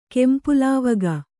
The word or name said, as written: ಕೆಂಪುಲಾವಗ